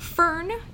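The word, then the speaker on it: firn: And then above that, you have what's called fern. It's not spelled like the plant. It's F-I-R-N.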